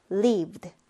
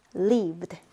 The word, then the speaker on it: lived: v 발음 같은 경우는 성대가 울리는 유성음입니다. v 했을 때 성대가 울리기 때문에 이때의 ed 발음은요. d로 발음을 해주면 됩니다. lived. lived 이렇게 발음해주면 되겠습니다.